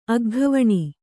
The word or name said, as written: ಅಗ್ಗವಣಿ